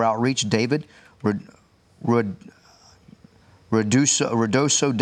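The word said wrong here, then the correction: Ruidoso: Downs, New Mexico. I don't think I pronounced that correctly. – Riadoso.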